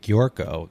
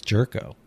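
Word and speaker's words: Gyorko: I would pronounce it Giorko, because that sounds better than Jerko. – I always call it Giorko. – It's Jerko. – No, I know it's Jerko.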